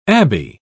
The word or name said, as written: abbey